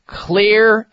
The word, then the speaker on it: Xlear: Clear is spelled with an X, as in X-L-E-A-R.